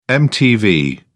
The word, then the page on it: mtv: British English